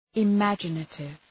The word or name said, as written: imaginative